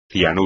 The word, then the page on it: cianuro: espagnol